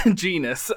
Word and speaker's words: Genus: It rhymes with penis. – Genus ice cream and Hellman's mayonnaise for their ads this week.